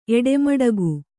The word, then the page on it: ಎಡೆಮುಡುಕ್ಕು: eḍemuḍukku